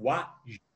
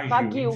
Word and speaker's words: wagyu: é o wagyu, se pronuncia oh. wagyu. – Oh. – Há quem fala wagyu, enfim, é wagyu.